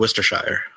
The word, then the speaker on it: Worcestershire: I know, but how do you pronounce this particular sauce then? – I would pronounce it Worcestershire. Worcestershire.